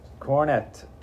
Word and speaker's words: Coronet: Coronet, $20 gold piece.